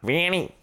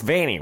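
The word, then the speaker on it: Vanney: Right, how do you say it? – Vanny. – It's Vanny, right? I said Vanny earlier, and that was wrong. – Yeah, it was. It's not like... varicose in your leg.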